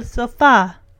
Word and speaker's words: sofá: She says, this is el sofá. And Danny's like, the sofa is el sofa? No, it's like she puts an emphasis on the A.